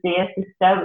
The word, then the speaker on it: Dyr Sister: I just wanted to correct you, Gareth. It's pronounced the Dyr Sister, which is my stage name and my current musical project.